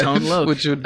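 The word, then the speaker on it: Tone Loc: Are you sure it's Tone Loke? Is that really true? Tone Loke? – 100%. – Okay. Wow, I should have looked that up. – I have been completely saying it wrong my entire life.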